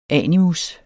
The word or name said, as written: animus